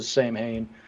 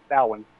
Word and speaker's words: Samhain: But because I like folklore and ancient history and all that stuff, I knew that the original pronunciation, though, is Samhain, you know, for even though it looks like Samhain, it's Samhain.